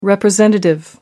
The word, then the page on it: representative: American English